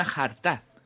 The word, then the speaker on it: jarta: Una frase que le digo a mi chica muchas veces, y que le diré mucha más, es te quiero una jarta, que significa muchísimo. Es decir, te quiero muchísimo. en esta ocasión, jarta se escribe con J y la vocal fuerte es la última A, deletreándose como J, A, R, T, esta variación es una forma que tenemos los andaluces de decir la palabra harta.